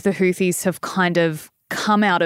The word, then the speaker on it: Houthis: Am I saying it right? Is it Houthis? – That's correct. Some people pronounce it Houthis, but it's Houthis with a hard H at the beginning.